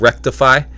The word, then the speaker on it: Wreck-Defy: Their name is called Wreck-Defy, uh, but it's spelled W R E C K uh, dash Defy, D E F Y.